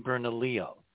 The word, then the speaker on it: Bernalillo: There's hope for Bernadillo. Maybe it'll start getting struck by lightning. – You mean Bernalillo? That's how you pronounce Bernalillo.